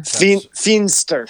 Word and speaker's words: Feenster: How do you say her last name? It's Fortune Famester? Femster? – Feenster. – Feenster. Okay.